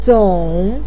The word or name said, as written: zong3